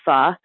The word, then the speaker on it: pho: It's it kind of goes by two names, Tank Noodle or Pho Zay Tang. And it's a big noodle shop. They're known especially for what Americans pronounce as pho, but is really technically pronounced more like pho. – And how do you spell that, pho? – You spell it P-H-O, which is why Americans call it pho.